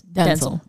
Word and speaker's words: Denzel: So the way you actually pronounce Denzel Washington's name is Denzel. – But he does now currently go by Denzel. – Like so. Zell, as in like Zell cash.